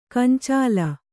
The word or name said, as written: ಗೊಂಚಲ್